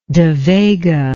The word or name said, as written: devega